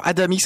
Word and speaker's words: Adamyk: Alors Adamick, ça s'écrit A-D-A-M-Y-K.